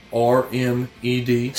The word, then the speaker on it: Wormed: That's WORMED. – See, I thought it was going to be WY. – No. Wormed, as if you are being dewormed.